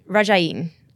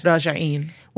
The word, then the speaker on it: Rajieen: Rajieen. Am I saying that correctly? – Yes. – Good. – Rajieen.